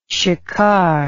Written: shikar

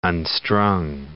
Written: unstrung

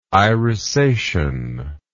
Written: irisation

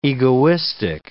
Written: egoistic

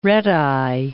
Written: red-eye

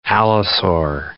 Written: allosaur

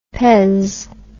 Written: pez

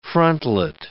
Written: frontlet

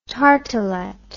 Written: tartelette

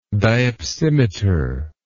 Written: diopsimeter

